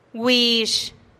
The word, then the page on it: güix: TdVZ